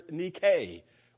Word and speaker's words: Nike: It is a form of the Greek word nike, which we, of course, spell N-I-K-E and pronounce Nike.